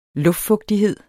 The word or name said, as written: luftfugtighed